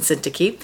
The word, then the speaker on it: Syntyche: He says, I entreat Euodia and I entreat Syntyche. If you were wondering how to pronounce those, hopefully that's helpful. Euodia and Syntyche.